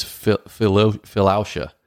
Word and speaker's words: philautia: And then the last one, I don't know exactly how to say it, but it's philautia. P-H-I-L-A-U-T-I-A, philautia, which is, honestly, self-love.